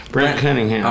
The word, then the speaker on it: Brent Cunningham: You know, Brent Cunningham. Did I say it right? – Brent Cunningham. – All right, yeah.